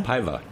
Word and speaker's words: Paiva: Uh, Pedro, how do you think you pronounce P A I V A? Portuguese. Paiva. – Paiva. – Paiva. Yeah.